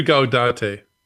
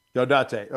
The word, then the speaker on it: Gaudete: This Sunday is the world famous, am I going to pronounce this right? Gaudete? – Right. I do Gaudete. – Gaudete. Okay.